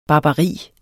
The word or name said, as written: barbari